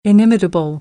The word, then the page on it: inimitable: American English; ɾ